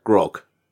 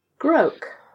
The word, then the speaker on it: Groak: What this one I'm not sure how to pronounce it, so I'm gonna call it Grok. Because it sounds like croak, but with a G. – So G-R-O-A-K. – G-R-O-A-K, yeah. Grok. – Grok, okay.